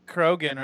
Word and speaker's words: Krogan: And they're still, you know, like, grudges that are held against each other from, you know, like the Krogan Crojan. Why? Because I was thinking, did I say that right? I just, like, totally brain farted. – Krogan, right?